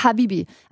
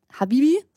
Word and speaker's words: Habibi: Dann sagt man es einfach normal und sagt Habibi so, aber eigentlich wird es mit einem betonten H ausgesprochen, mit einem H, das geht noch.